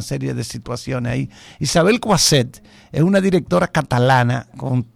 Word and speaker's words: Coixet: Entonces, es una muy buena directora, repito el nombre, Coixet, se escribe Coixet con X, Coixet, pero ella es catalana.